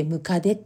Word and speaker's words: 百足: ちょっと私もねにわかには信じられないんですけどね、百足と書いてムカデって読むんですよね。